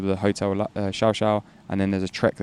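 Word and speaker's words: Llao Llao: So one of the treks I did was the Xiao Xiao trek, which is spelled LLAO, Laolao, it would be in English, but the Argentinians pronounce it Xiaoxiao,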